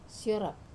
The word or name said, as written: siorap